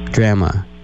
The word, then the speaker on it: drama: I would simply advise you to use the American and or British pronunciation of the word D-R-A-M-A. – Drama. – No. Drama. – Drama. – Yeah. You will just throw them from the roller coaster. If you say drama, you will lose them almost immediately. They will think he said drama.